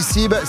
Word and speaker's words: Seeb: Et avant 7h30, Sib, ça s'écrit S-E-E-B, Sib, c'est ça? – Control, control, control, control, control.